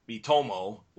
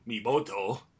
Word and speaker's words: Miitomo: But we did talk about Miitomo, which I was calling Miboto, last week.